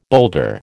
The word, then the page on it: boulder: American English